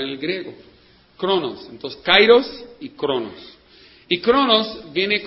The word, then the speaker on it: kairos: Se escribe K-A-I-R-O-S, kairos, como el pueblo de Cairo, ¿no?, de Egipto.